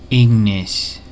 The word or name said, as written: fire